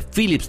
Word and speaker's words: Philips: El premio de Philips TV and Sound será tuyo.